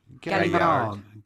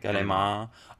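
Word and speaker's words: Gallimard: Galimard. – You wouldn't pronounce the D. – Galimard.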